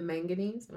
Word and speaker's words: manganese: So what is the manganese? Am I saying that right? – Yeah.